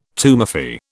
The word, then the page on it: tumefy: British English